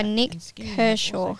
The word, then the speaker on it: Nick Kershaw: We're now going to be playing Wouldn't It Be Good by Nick Kershaw.